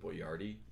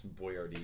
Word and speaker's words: Boyardi: Probably the way you're pronouncing Boyardi. It's Boyardi.